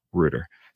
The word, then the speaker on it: router: because in America it's pronounced router. But just to be clear, I am from the United Kingdom, and look it up if you don't believe me. The correct pronunciation in the United Kingdom is router.